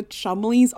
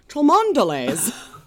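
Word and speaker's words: Cholmondeley: The best is that Chumley is spelled C-H-O-L-M-O-N-D-E-L-E-Y-S. So if I were to just try to pronounce it, I would say Chumondeles. – And they'd say, excuse me, it's Chumleys.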